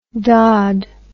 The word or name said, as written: daud